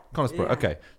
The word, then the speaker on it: Conisbrough: So is it pronounced Conisbrough in Yorkshire? – Yeah. – Conisbrough, okay.